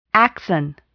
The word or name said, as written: axon